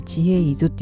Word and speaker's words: 知恵泉: あの知恵ですね知識の知恵の泉って書いて知恵伊豆って読む